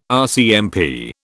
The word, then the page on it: RCMP: British English